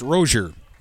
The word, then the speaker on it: Roeser: It is spelled R-O-E-S-E-R, but pronounced Rozier.